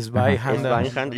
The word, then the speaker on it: Zweihander: Ah, pues ¿Así se pronuncia? Sí, Zweihander. Yo siempre he dicho Zweihander.